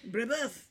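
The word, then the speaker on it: Brébeuf: Brebeuf.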